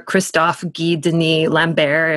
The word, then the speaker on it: Christophe Guy Denis Lambert: I would be in a busy coffee shop and Christopher Lambert would be behind me or Christophe Guy Denis Lambert, if you pronounce it correctly.